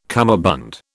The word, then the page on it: cummerbund: British English